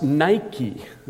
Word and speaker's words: Nike: I don't know if that sounds familiar to you, but as a noun, it's pronounced Nike.